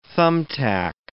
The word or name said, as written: thumbtack